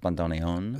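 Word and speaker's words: bandoneón: And so it's when it got adopted by the Argentinians that the word acquired the accent at the end, where it's usually pronounced bandoneon.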